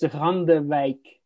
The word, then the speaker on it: Randewijk: Well, it's a Dutch name, so it's inherently difficult to pronounce, but it's Randewijk.